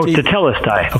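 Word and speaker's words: tetelestai: I'm going to telestia. Is that T E T? Oh, telestai.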